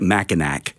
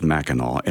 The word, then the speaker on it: Mackinac: And it looks like Mackinac, but it's pronounced Mackinac, and I would highly recommend anybody going there.